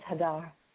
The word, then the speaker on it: Hadar: It's Hadar.